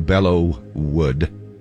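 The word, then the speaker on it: Belleau Wood: Garth Brooks with that song, Bella Wood. It's spelled B-E-L-L-E-A-U, Bellow Wood.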